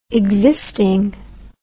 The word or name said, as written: existing